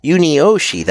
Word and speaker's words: Yunioshi: Yunioshi, that's it.